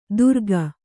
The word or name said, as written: ತುರಕ